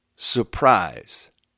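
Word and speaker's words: Sue'Prize: She said, I've also had Sue apostrophe Prize, pronounced Surprise. That is a name.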